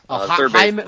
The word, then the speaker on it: Jaime: Jaime Candelario. – Yeah, Jaime Candelario. They don't have him. – It's a silent J or soft J.